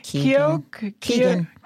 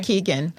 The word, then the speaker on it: Keoghan: And then you got Barry Keoghan. – Yeah. Thank you. – It's spelled the Irish way, but it's Keegan.